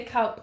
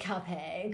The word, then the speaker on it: Calpe: I think it depends if you're Spanish or not. – Okay. – I think you say Calp or Calpe. – Oh, well, let's go with Calpe because it sounds Calpe.